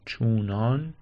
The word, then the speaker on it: چونان: این کلمه هم چنانه، حالا همه چنان میگن، چون، چونان، چنان، چون که چن نمیشه که. و جالبه که سابقه هم داره این تلفظ چنان، نوشتنم که در ایران اینجور تلفظ میشه، ولی با همین استدلال دقیقا که چون آن میشه چونان. و حالا میگم دیگه چنانم میگن و از قدیم هم میگن چونان.